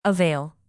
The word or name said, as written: avail